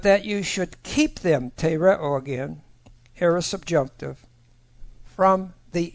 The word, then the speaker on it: tereo: The first one is tereo, T-E-R-E, long O.